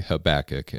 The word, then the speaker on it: Habakkuk: We're going to be here in one of the most fun parts of the Bible to pronounce, which is Habakkuk. Okay, Habakkuk.